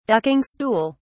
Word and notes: ducking stool: British English

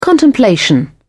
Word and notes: contemplation: British English

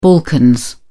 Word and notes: balkans: British English

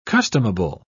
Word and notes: customable: British English

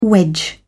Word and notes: wedge: British English

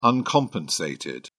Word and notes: uncompensated: British English

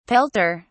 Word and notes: pelter: British English

